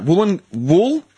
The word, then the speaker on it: Woolloongabba: Woolloongabba. – Woolloongabba. – Like a phoenix rising out of Woolloongabba. – Let me get the correct pronunciation for you. Wait, it's I was right. Woolloongabba.